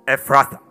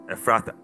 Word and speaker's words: Ephrathah: Ephrathah. P-H is F. Eh? Ephrathah. Bethlehem, a place of bread. Ephrathah, a place of fruitfulness. It signifies, it means fruitfulness. It means double fruitfulness. So Bethlehem Ephrathah, place of supply, a place of bread, a place where one can get.